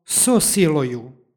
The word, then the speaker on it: so siloju: stihu pročitali smo so siloju. Tu imamo ono pravilo o dodavanju izgovornog o kada predlog i sljedeća reč počinju istim glasom. Između njih se dodaje izgovorno o radi lakšeg izgovora i preciznijeg prenošenja značenja. Isto imamo i u 20.